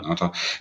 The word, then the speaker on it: Ngata: Da konnten sie irgendwie nicht so richtig gut auftreten, weil Sigi Ansah und Haloti Ngata, ich glaube, das spricht man Nata. – Nata.